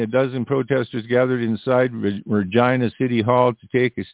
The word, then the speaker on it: Regina: Face the backlash after certain slogans and phrases joking about Regina rhyming with vagina appeared on the agency's social media.